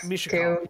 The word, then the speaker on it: Mishakal: At some point, we need to figure out how Mishakul, Mishakal is pronounced. It's Mishakal. – Too. – Mishakal. – Mishakal. – M-I-S-H-A-K-A-L.